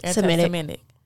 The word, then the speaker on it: anti-Semitic: Anti-Semitic.